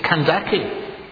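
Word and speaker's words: Kandake: Can I just tell you, that is a mispronunciation, not a mistranslation. This is an anglicized version of a title. And it should actually be K-A-N. K-A-N-D-A-K-E. Candace. Not Candace. We've Latinized it. We've anglicized it. It's Candace.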